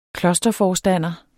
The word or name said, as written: KLOSTERFORSTANDEREN